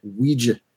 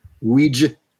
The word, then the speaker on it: Ouija: The little girl finds a Ouija board and I think that's how you're supposed to pronounce it. Ouija. – Whatever. – That's how they pronounced it in the movie. Ouija.